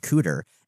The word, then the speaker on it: CDR: There's one called CDR, and you pronounce it Cooter.